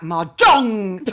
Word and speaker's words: Mahjong: I thought it was a silent J. – No, it's not. It's a loud J. It's, in fact, Mahjong, like that.